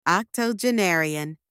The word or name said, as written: octogenarian